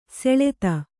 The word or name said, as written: ಶ್ಲಥ